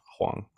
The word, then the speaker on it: Hwang: So I learned that it is indeed pronounced Hwang. – Really, my fault. Be honest, Huang sounds a lot better.